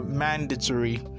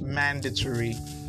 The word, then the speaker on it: mandatory: Now in this word, the stress is always on the first syllable, so you go mandatory. Okay, so please take note, it's pronounced mandatory.